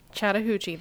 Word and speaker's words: Chattahoochee: Chattahoochee River. – Chattahoochee, that's it. – Chattahoochee, okay. So it is spelled exactly the way it sounds.